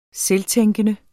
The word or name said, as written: selvtænkende